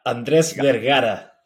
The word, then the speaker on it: Andres Vergara: Did I pronounce it correctly or did I butcher the last name? I've been to Latin America long enough. – Andres Vergara. – Vergara.